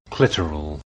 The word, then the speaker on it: clitoral: Clitoral. – Clitoral. I guess I have been saying it wrong. It rhymes with literal, not amoral.